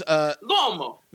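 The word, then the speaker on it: L'uomo: It's just Lomo. – It's L apostrophe U O M O. It's Italian. So it's Lomo. – Lomo Nero.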